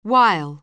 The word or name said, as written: wile